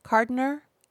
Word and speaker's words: Kardiner: van der Kolk in his in his practice, in his schooling, in his continual learning came across a book by Abram, I hope I say this right, Kardiner. Kardiner, K A R D I N E R, describing his observations of World War I veterans.